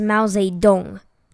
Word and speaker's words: Mao Zedong: To all those Americas, Miao Zedong, which is not the correct pronunciation, guys. It's Mao Zedong.